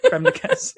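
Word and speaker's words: crème de cassis: So cream of cases, having since. – Creme de cassis.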